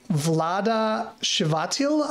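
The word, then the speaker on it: Vlada Chvátil: It's by Vlada Shivatil. I don't know how to pronounce. Is that pronouncing that right? – As far as I know, that's pretty close, yeah.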